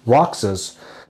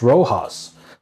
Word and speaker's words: Rojas: And then you said my wife's maiden name, but you mispronounced it. You said it phonetically, Roxas, but it's pronounced Rojas.